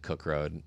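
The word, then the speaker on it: Koch: I think you pronounce it Cook Road. It's like, but it's spelled. – It's off. It's K O C H.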